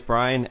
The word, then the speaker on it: Bryan: And uh, my name is Bryan, and it is spelled just like it sounds.